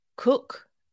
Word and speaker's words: Coke: And a lawyer called Cook, if you look him up, it's spelt Coke, C-O-K-E, as far as I think.